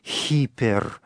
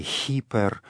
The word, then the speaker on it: гипер: Так происходит потому, что в этой греческой приставке в древности был вначале особый призвук, так называемое «густое придыхание», которое перестало произноситься в дальнейшем, поэтому в средневековом византийском чтении «ипер» с буквы «и» будет начинаться, но латинский язык сохраняет древнее греческое произношение, там буква «х» на первом месте, «хипер», отсюда «гипер», в русском языке.